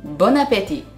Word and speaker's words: Bon appétit: べる前にフランス人は何というかご存知ですかそうボナペティボナペティボナペティと言いますねこのボナペティボンとアペティの間のリエゾンを忘れないようにボナペティと発音してください